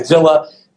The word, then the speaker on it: Villa: If you ask my late grandfather, he was born in Cuba. So the correct pronunciation is the Hispanic pronunciation. But I go by Villa.